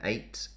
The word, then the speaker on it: ait: However, at this pub, at one over the eight isn't spelt like the number, it's spelt A-I-T. Mm-hmm. And that's because an eight, in that sense, is a small island, particularly in the Thames.